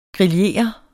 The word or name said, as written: grillere